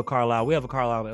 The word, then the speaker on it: Carlisle: Okay, it's Carlisle, Pennsylvania, but it's spelled Carlisle. – C-A-R-L-I-S-L-E. Yeah, Carlisle.